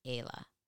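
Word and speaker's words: Aila: It's a mauve-ish color, and the brand is A-I-L-A. I believe you pronounce it Ayla.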